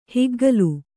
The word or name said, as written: ಹೆಗ್ಗೋಲಿ